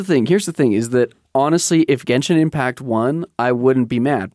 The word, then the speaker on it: Genshin Impact: Fortnite and Genshin Impact. – You didn't say that right. You got a Genshin Impact. – Genshin Impact. – There we go.